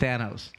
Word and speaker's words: Thanos: It's just Thanos.